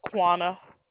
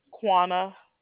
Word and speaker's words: Quanna: Quana. – Okay, I don't know what you said. – Quana. – Could you please spell that for me? – Q-U-A-N-N-A. – Q-U-A-N? – Yes.